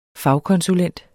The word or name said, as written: fagkonsulent